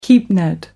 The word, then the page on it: keepnet: American English